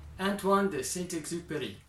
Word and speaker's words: Antoine de Saint-Exupéry: I'll be honest, his name is hard to pronounce. I did a search on YouTube to find his pronunciation. Let me try it. Antoine de Saint-Exupéry.